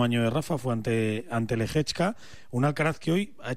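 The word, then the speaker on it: Lehečka: A las 11 de la mañana, hora española, la final de Tokio ante Lehechka.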